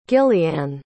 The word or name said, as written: gillianne